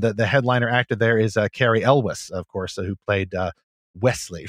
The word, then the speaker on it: Cary Elwes: So I wanted to go and confirm it's Cary Elwes is how you say that.